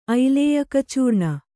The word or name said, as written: ಐಲೇಯಕಚೂರ್ಣ